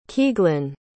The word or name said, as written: Keaghlan